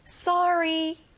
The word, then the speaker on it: sorry: 미안한, sorry. 자, 소리가 아니에요. Sorry.